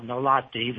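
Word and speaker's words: Gnaulati: Let me pronounce, I pronounced his name not too well, Nolatti. The G is silent, and his last name is spelled G-N-A-U-L-A-T-I. G-N-A-U-L-A-T-I.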